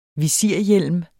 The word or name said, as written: visirhjelm